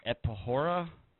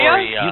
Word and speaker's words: Euphoria: Epo- Epohora, About Euphoria? Euphoria.